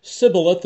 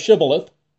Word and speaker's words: Shibboleth: You said Sibboleth instead of Shibboleth.